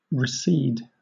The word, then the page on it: recede: British English